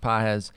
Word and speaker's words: Páez: It's spelled like Pages. It's pronounced Páez.